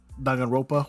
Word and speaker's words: Danganronpa: Dan Gang Rampa. – Danganronpa, which is actually a really good one.